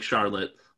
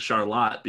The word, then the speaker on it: Charlotte: It's spelled like Charlotte, but they pronounce it Charlotte because of the French-Canadian influence.